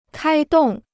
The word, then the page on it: 开动: kāi dòng